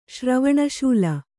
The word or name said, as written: ಶ್ರವಣಶೂಲ